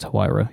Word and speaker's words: Huayra: Is that how we pronounce that? – I'm pretty sure it's Huayra, yeah.